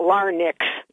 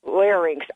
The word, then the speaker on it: larynx: it's not larynx, it's larynx, R-Y-N-X, okay, which people seem to mispronounce.